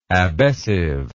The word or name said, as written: abessive